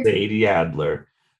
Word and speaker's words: Sadie Adler: So you got Sadie Alder. Is that how you say her name? – Sadie Adler.